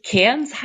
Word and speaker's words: Cairns: Also oben hast du schon richtig, Cairns heißt ausgesprochen.